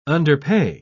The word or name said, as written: underpay